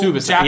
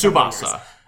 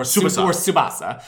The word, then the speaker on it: Tsubasa: I'm here as our local Tsubasa. Japanese pronunciation guide who has not properly spoken Japanese. – So it's Tsubasa. – It's Tsubasa or Tsubasa. – Okay. – It's usually like Tsubasa.